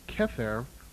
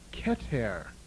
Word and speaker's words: Keter: You will also find the word Kether, which in actuality should be pronounced Keter.